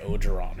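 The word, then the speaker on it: Orgeron: Or it looks like Oregon, but it's like Ogeron or something like that.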